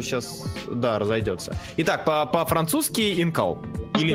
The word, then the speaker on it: Incal: об этом, но э, по французским правилам ударением на последний слог. Инкал.